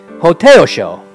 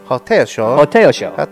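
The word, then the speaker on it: Hotei Osho: Buddha's cousin, Hōtin Sisho. – No. – How you say it? – Hōtei Osho. – Hōtei Osho. – That's right.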